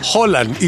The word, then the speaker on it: Haaland: Que, Erling Haaland. – Haaland. Y se escribe distinto, se escribe con doble A.